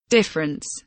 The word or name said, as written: difference